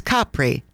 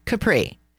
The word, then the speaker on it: Capri: And just so all of you get the pronunciation correct, it's Capri, not Capri.